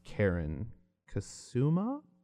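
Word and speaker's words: Karyn Kusama: Maybe that leans to like directed by, I believe it is pronounced Karen Kasuma, a woman.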